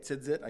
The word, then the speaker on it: tzitzit: It is interesting also that the numeric value for the tassel, which in Hebrew is tzitzit, that's how they say it, tzitzit, I guess. It's a hard, not an easy word, but that's kind of the way it sounds.